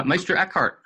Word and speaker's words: Meister Eckhart: Meister Eckhart.